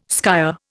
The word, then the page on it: skyer: Indian